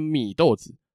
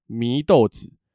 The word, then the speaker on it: 祢豆子: 卡玛多 Nezuko，它的祢豆子的祢啊，很多人会念成米豆子，三声米，但其实它的读音是二声祢豆子。